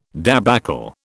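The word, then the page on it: débâcle: British English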